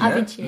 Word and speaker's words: Avicii: Avicii, ja.